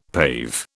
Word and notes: pave: British English

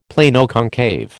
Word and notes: Planoconcave: American English

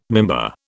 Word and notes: mimbar: British English

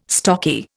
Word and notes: stocky: Indian

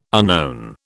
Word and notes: unknown: British English

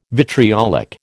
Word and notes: vitriolic: American English

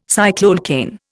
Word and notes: cycloalkane: Indian

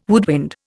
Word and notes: woodwind: Indian

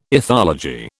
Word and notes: ethology: British English

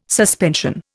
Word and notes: suspension: Indian

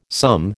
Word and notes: sum: American English

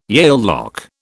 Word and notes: Yale lock: British English

